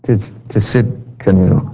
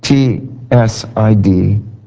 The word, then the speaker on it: Tsidkenu: Jehovah Tsidkenu. Boy, it's a hard one to say. I'll spell it for you. Tsidkenu. Tsidkenu. Jehovah Tsidkenu, the Lord is our righteousness.